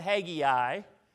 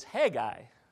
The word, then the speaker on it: Haggai: Not Haggai. It's Haggai, all right? And I can't say it like it's pronounced in Hebrew. Haggai.